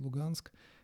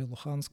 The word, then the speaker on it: Луганск: И потом еще а, выяснилось то, что вот на западе Украины и на востоке, да, вот где Донбасс, вот этот Луганск или Луханск, правильно говорить, там тоже нашли очень большие запасы нефти и газа.